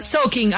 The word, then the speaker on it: soaking: porque Caro decía al principio soaking, puede ser soaking, o puede ser soaking. Mi marido está acá, que es americano, amor, se dice soaking, ¿cómo se dice? ¿Soaking? Soaking. Ah, bueno, estamos bien.